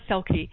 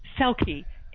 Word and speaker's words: Selke: Well, go to Amazon, type in my name, Ilona Selke, and it's spelled with an I. So remember, I like you and me and I, Ilona, I L O N A, Ilona Selke, S-E-L-K-E dot com or Ilona Selke on Amazon.